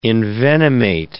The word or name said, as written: envenomate